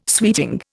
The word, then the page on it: suiting: Indian